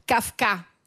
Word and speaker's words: Kafka: Poi eh, un barista che era un po' filosofo lo, chiamavano, eh, lo chiamavamo Kafka con l'accento sulla A.